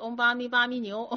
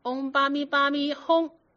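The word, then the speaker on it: 唵嘛呢叭咪吽: 嗡巴咪巴咪牛，嗡巴咪巴咪牛，他一进去一看，哦，是个老，在外面一看是个老太太，他就跟老太太说，老太太啊，这个字应该念成轰字，不能念成牛，应该念嗡巴咪巴咪轰，嗡巴咪巴咪轰。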